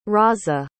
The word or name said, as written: Raza